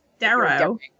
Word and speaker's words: derro: Dero? – Duo? – Yeah. Dero. Duo? It's spelled D-E-R-R-O. – Dero.